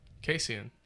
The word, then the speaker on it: Kasian: K-A-S-I-A-N. – Kassian.